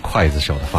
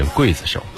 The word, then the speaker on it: 刽子手: 冰雨来自刘德华，很多朋友呢听到这歌呢可能都会记得当中的那个刽子手的发音哈，其实应该念刽子手，呵呵。